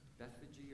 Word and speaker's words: Bethphage: As they approached Jerusalem and came to Bethphage, I always want to say Bethphage. Which one you like best? Bethphage or Bethphage? If you Google it, it can be pronounced either way. I'm a Bethphage kind of fan, so we'll go with Bethphage and Bethany at the Mount of Olives.